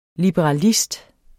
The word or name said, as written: liberalist